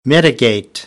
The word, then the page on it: mitigate: American English